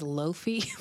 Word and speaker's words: Lofie: Wait, her name is Lofie? – Look, it's spelled L-O-F-I-E. – Okay. – Lofie, Lofie. Okay.